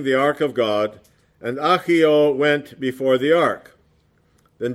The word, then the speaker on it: Ahio: actually that's pronounced Ahio, the sons of Abinadab, drove the new cart.